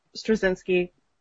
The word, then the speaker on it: Straczynski: Michael Straczynski, who I can't pronounce his last name very well, so we're just going to call him JMS. – I think you pretty much nailed it there, actually.